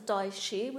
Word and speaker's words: Daishi: That's a Daishi with an SH, and Daichi is with a CH, so we can pronounce that. We're not French, so we can distinguish the difference between those, if you recall them.